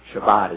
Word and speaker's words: Shabbat: Well, if you're going to keep Shabbat is how it's pronounced.